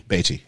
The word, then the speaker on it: Beatty: Beatty or Beatty? How do you say it? – Beatty, yeah. – Yeah, Warren like, Beatty wanted it in black and Baywatch. – White. Baywatch, Beatty. – Yeah, like Bay and a cup of tea.